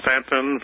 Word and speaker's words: Staunton: At 126 Madison Place, and that's Stanton, Virginia, 24401. – And Stanton is spelled what now? S-T-A-N-T-O-N? – S-T-A-U-N-T-O-N. The U is silent. – Okay, S-T-A-U-N-T-O-N.